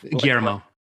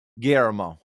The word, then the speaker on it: Guillermo: Guillermo. – Guillermo.